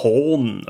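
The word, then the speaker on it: Hän: Wir gehen zu Hahn also dieses H-A-N, aber das A mit so einem, ja, mit so einem Pünktchen obendrauf. Ich weiß nicht, es wird wohl, soweit ich weiß, Horn, also wie Hohn ausgesprochen.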